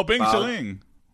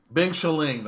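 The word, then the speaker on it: Bing Shiling (冰淇淋): How do you say, what's the word he says in Mandarin, that's ice cream? – Oh, Bing Shiling. – What is it? – Bing Shiling. – Bing Shiling, that's right.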